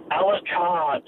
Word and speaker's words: Alucard: How do you pronounce it again? – Alucard. So A-L-A-C-A-R-D. Alucard.